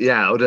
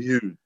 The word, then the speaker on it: Youd: I've been pronouncing it Yaud, and then I found out recently that's wrong. And it's Sam Yood. So it's spelled Y-O-U-D,